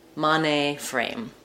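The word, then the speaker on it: mainframe: Generally, of course, we tend to stress the last name when we're talking about two names, but Mane is stressed on the first syllable. Mane frame.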